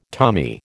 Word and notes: Tommy: American English

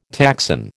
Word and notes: taxon: American English

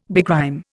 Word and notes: begrime: Indian

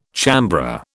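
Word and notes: chambré: British English

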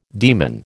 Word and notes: daemon: American English

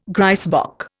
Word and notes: grysbok: Indian

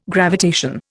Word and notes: gravitation: Indian

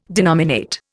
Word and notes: denominate: Indian

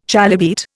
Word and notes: chalybeate: Indian

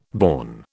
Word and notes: born: British English

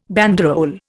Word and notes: banderole: Indian

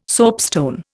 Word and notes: soapstone: Indian

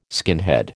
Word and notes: skinhead: American English

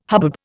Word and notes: hubbub: Indian

